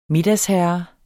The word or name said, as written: middagsherre